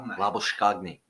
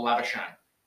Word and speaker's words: Labuschagne: All right, next one we've got up here is a current Australian cricketer, Manus, and I'm pretty sure Harsha Bogla pronounces it, Labuschagne. – Well done. – Or Labuschagne.